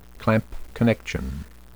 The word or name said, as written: clamp connection